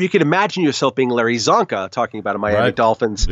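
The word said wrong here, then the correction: Csonka: Chonka is how you pronounce that.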